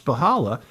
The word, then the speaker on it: Spychalla: Her last name is spelled S-P-Y-C-H-A-L-L-A. And the reason I know it's pronounced Spahala is because that was the name of a family I grew up with in Matamida.